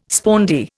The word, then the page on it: spondee: Indian